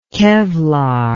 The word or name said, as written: kevlar